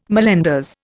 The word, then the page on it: mallenders: Indian